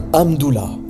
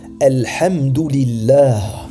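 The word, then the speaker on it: Alhamdulillah (الحمد لله): Ils disent par exemple amdullah et ceci signifie, si on traduit mot à mot, le prolongement d'Allah.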